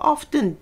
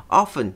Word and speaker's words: often: で、この発音としては often で t をつけてもいいですし、 often っていう人もいます。